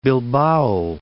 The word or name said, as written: Bilbao